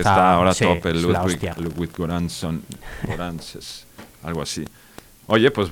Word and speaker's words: Ludwig Göransson: Ah, sí, y el autor, bueno, seguro lo pronunciaré fatal, pero es Ludwig Göransson o algo así, es sueco.